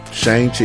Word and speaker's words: Shang-Chi: All right, so next up, I'm hoping I'm pronouncing it right, is it Shang-Chi? – Shang-Chi, yeah.